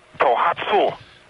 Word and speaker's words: Tohatsu: Okay, you have Tohatsu Outboard Motors. – Tohatsu? – Tohatsu. – Jeez. Okay. – You got to pronounce it properly. – All right. Tohatsu.